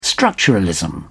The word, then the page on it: structuralism: British English